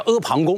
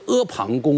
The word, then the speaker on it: 阿房: 北京大学汉语史学科的开创者啊，著名学者王立先生认为这个房就是旁啊，旁边的旁啊。你看看这读音也很相似。所以阿房是什么意思呢？就是在一个大山的旁边的一个宫殿啊，叫阿房宫。那这个有没有资料作为佐证呢？有。所以秦始皇本纪里面就专门记载了，说这个阿房宫啊，坐宫阿房，故天下谓之阿房宫。